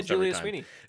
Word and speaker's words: Julia Sweeney: The payoff comes when Julia Sweeney. Did I have it right then? – Mm-hmm. – I'm saying her name wrong. – Yeah, Julia Sweeney.